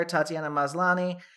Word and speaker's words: Tatiana Maslany: Claire Danes, Homeland, Viola Davis, How to Get Away with Murder, Taraji and on Empire, Tatiana Maslany, Orphan Black, Carrie Russell, The Americans, Robin Wright.